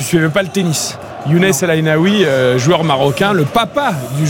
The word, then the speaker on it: El Aïnaoui: El Aïnaoui, comme ça qu'on prononce. – Tout à fait, El Aïnaoui